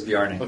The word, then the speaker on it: Bjarne: So, you know, now that we're in sort of, as I guess Bjarne is how he pronounces his name. Bjarne, I always pronounce it wrong. Herb Sutter always corrects me, and I can't remember what he says. – Herb says Bjarne. – Okay, Bjarne. So, yeah.